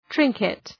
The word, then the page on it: trinket: ‘trıŋkıt